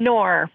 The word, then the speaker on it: Noer: Do you know how to pronounce his name? It's O.J. Noor, N-U-E-R. – Oh, Noor. – Noor? – O.J. Noor, okay. N-O-E-R, right? – Yes, yes.